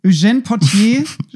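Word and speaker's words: Eugène Pottier: Der Text im Original auf Französisch von, da musst du mir dann wieder helfen bei der Aussprache. Eugène Potier, würde ich sagen. Okay. Eugène Potier schrieb den Text dazu.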